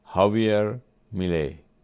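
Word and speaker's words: Javier Milei: و بعدم اینا باز عکسای دیگه ای از ترامپ و این آقای خاویر ملی فکر ما میام چه اسمی داره، ببخشید من زیاد تلفظ اسمم خیلی خوب نیستم، هاویر میلی اینجوری میگن.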